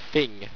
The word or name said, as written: phing